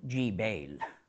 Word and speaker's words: Jebel: In Arabic today we say Jebel, with a soft G.